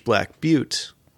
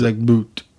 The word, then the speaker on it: Black Butte: Or Black Butt, depending how you pronounce it. – Black Butte. – Butte.